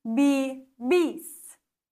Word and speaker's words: vivís: Jede Silbe, die einen Akzent trägt, musst du betonen. Das heißt, du betonst hier bei habláis das Eis. Du betonst aprendéis. Du betonst vivís. Wichtig, dass du die Silbe mit Akzent betonst.